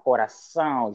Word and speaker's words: coração: but there is this accent that is pronounced nasally, coração, João, galegão, portão, that sound, it took me virtually weeks to get it.